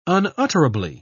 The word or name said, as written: unutterably